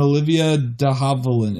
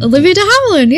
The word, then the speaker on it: Olivia de Havilland: Has Errol Flynn and Olivia de Havilland in it. Who's the name I just butchered? – Olivia de Havilland, yeah.